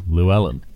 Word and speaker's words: Llewellyn: Llewellyn.